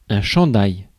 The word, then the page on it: chandail: [ɛ̃ ʃɑ̃.daj]; /ʃɑ̃.daj/